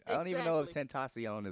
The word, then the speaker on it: Tentacion: He spells it T-E-N. – But T-A-C-I-O-N. It's pronounced Tentacion.